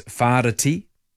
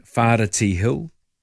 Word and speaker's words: Whāriti: The Big Hill in Palmerston North in the Manawatū region is known as Whāriti. I don't know how many people would say it like that, but that's the correct pronunciation, Whāriti Hill.